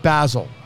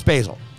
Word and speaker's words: Basil: I thought because it was a person's name, you pronounce it Basil. And I've oh and and I didn't actually always think that. Someone shared that with me. I'm like, okay, that makes sense. Nope, it's Basil. It's Basil Hayden.